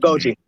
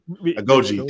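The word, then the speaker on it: Agojie: Because, like, if you're focusing the movie on, like, Nanesca and the Okoye, or I can't pronounce the fucking Agoji. – Agoji. Thank you.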